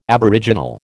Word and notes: aboriginal: American English

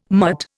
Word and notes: mutt: Indian

